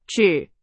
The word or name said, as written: 指